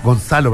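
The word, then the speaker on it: Gonçalo: Se llama Gonzalo, pero se escribe Goncalo.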